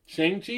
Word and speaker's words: Shang-Chi: Shang-Chi, is that how you pronounce that? – Mm-hmm.